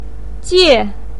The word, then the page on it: jie: 4